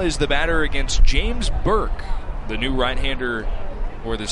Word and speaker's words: Bourque: James Burke spells his name B-O-U-R-Q-U-E, but pronounced Burke.